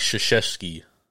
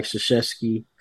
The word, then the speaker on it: Krzyzewski: Mike Krzyzewski. – You hear Mike Krzyzewski.